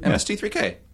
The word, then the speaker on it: MST3K: And, but we went to MST3K. – Oh, right. – Why can I never say this? – You said it right. – Oh, I did? – MST3K.